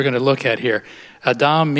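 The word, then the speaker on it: Adam (אָדָם): Now, that Hebrew word is, according to the Hebrew scholars, it's pronounced Adam. The accent's on Dom. Now, that word we say in English is Adam, okay? But in Hebrew, it's Adam.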